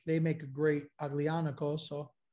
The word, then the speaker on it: Aglianico: Like Aglianico, is that how you pronounce it? – Aglianico, yes. That's Aglianico.